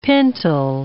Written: pintle

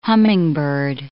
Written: hummingbird

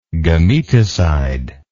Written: gametocide

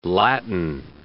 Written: Latin

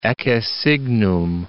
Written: ecce signum

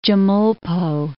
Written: Chemulpo

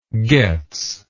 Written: Gotz

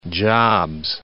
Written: jobs

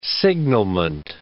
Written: signalment

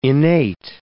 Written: innate

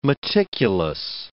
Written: meticulous

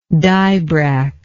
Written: dibrach

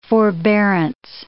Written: forbearance

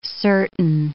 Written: certain

